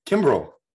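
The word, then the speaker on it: Kimbrel: Wait, how do you say his name now? I can't even think. – Kimbrell. Is it? – Yeah.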